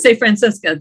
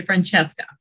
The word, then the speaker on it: Francisca: This is Francisca. Did I say that right? Okay, Francisca Noronha. Yeah, Francisca Noronha, because some people would say Francesca.